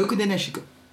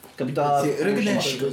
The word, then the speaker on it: Rokudenashiko: Rokudenashiko. Voilà. Voilà. – Rokudenashiko.